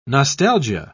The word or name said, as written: nostalgia